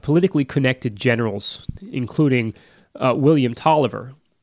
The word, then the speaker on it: Taliaferro: Now, for anyone taking notes, Tolliver is spelled exactly like it sounds, T-A-L-I-A-F-E-R-R-O.